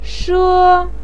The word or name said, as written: she1